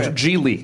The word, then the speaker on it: Gigli: It's pronounced Gigli. – Yeah, Gigli. – But it Gigli. – Is. – I can get it. – It's spelt Gigi, but it's pronounced Gigli.